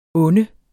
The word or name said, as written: unde